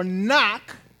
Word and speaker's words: knock: Seek, that's the S in the A-S-K. Seek, and you will find. The K stands for knock. Now, I know that sounds funny because the K is silent in that word, but it's still there.